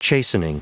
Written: chastening

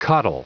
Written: coddle

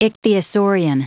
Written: ichthyosaurian

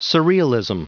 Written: surrealism

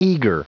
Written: eager

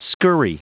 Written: scurry

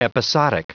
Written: episodic

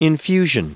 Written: infusion

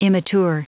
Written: immature

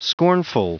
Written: scornful

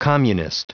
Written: communist

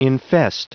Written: infest

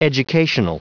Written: educational

